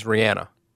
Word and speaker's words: Rihanna: And did you also know that the correct pronunciation, I saw this online, is Rihanna? – No, like, I didn't. – Yeah. – I still say Rihanna.